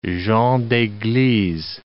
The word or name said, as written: gens d'eglise